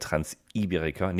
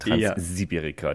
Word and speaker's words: Transiberica: Nein, es kommt auf die genaue Betonung an. Du warst bei der Transiberika, nicht Transsibirika.